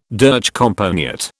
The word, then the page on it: durchkomponiert: British English